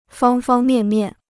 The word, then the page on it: 方方面面: fāng fāng miàn miàn